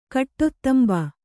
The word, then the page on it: ಕಾಡತುಂಬೆ: kāḍatumbe